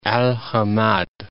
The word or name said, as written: Hamad, Al